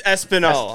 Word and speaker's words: Espanol: I'm going to pull up her audio recording that she sent me to make sure I'm pronouncing this name correctly. Okay. She told me she was eating dinner. It's Espanol. Espanol. Okay. Espanol. All right.